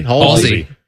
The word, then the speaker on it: Halsey: Halsey? Am I saying that right? – Oh, Halsey. – Halsey.